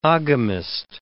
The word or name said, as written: oghamist